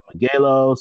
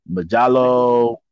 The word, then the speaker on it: Megalobox: You could pronounce it a couple of different ways. I say Megalo, some people say Majalo, Majalos.